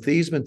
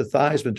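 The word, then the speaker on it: Theismann: But we think by just changing the pronunciation of your last name from Thiesman to Thiesman to rhyme with Heisman, we can get you that trophy.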